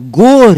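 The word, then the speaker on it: горе: Et quand on change l'accent tonique, le russe, c'est une langue à accent. Gara, montagne, devient gore. Et ça, ça veut dire le malheur.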